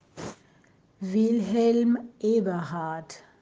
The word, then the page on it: Wilhelm Eberhardt: German